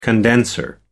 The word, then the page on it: condenser: American English